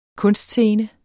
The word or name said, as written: kunstscene